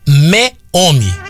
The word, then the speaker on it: Meomi: Então, se você quiser experimentar um vinho gostoso, chama Meome. Meome. M-E-O-M. Peraí, M-E-O-M-I. Chama Meome. Meome. Como se falasse meio homem.